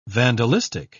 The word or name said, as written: vandalistic